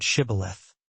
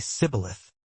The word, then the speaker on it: Shibboleth: Say the word Shibboleth. But the Ephraimites, due to a dialectical difference, could not pronounce the SH sound correctly. They would say Sibboleth, a small, linguistic difference.